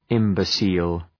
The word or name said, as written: imbecile